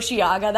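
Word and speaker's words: Osheaga: Oceaga. That's how the Canadians pronounce it, so that's how I gotta pronounce it, yeah.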